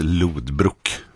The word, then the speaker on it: Lothbrok: Och så skäms jag för att jag sa Lothbrok. Det där misstaget kan man göra, för det är ju det man har hört i serien Vikings. Men de har ju bara översatt ett namn som ju mycket riktigt ska uttalas Lothbrok från början. Så det ska vara rätt. Här är George Michael, låten heter Faith.